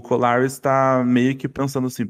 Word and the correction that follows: Corlys: É, é amigo, que eu falei, eu falei falei errado, o nome dele é Corlys Velaryon. Corlys.